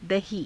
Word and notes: dahii: hindi

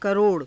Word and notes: karo ra: Hindi

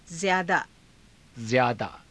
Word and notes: zyaadaa: Hindi

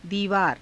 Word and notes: diivaara: hindi

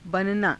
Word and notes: bananaa: hindi

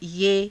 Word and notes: ye: Hindi

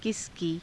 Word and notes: kisa kii: Hindi

